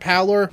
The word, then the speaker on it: pallor: Morals turn to brick and mortar at the mere sight of my ghastly, corpsey, ghostly, what's that word, pallor? Parlor? How do you pronounce that? – Pallor, I believe, pallor, and I believe you should know the lines.